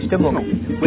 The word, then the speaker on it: Stimmung: Yep, Stimmung.